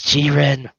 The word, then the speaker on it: Jiren: And this guy, his name pronounced in Japan in the subs is Jiren. But everyone in the dubs is calling him Jiren. Is like, no, Jiren. Jiren. His name's Jiren.